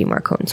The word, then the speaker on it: Marcone: So I was like, okay, how does Jim Butcher pronounce it? So, assuming that Butcher will always pronounce it the way he wants it pronounced and that he's not caving to peer pressure, I'm going to assume that it is meant to be Marcone, so we will call him Marcone.